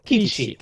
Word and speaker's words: Kiçi: Did I get that Kiçi. – Right? – Kiçi.